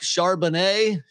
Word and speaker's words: Charbonnet: I'm going Zach Charbonnet.